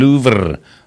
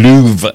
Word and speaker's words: Louvre: 1911 op hierdie dag word die Mona Lisa gesteel uit die Louvre, of die Louvre eintlik, dis seker die korrekte uitspraak, uit die Louvre, deur Vincenzo Perugia.